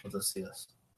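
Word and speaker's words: Notícias: Uh, until his death in 1970, aides gave Mr. Salazar a fake version of the newspaper Diario de Nositas each day, which carried fictional stories to make it seem like he was still in charge. Noticias. Noticias. Sorry, I said that wrong.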